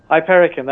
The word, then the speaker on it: Hypericum: Hypericum, is that right? – Hypericum, that's right.